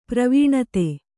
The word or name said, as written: ಪ್ರವಣತೆ